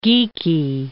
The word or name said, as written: Geikie